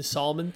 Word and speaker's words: salmon: This is like the fourth time you've used the salmon thing. – Though. I know. – But it's the soft L, the silent L that he's.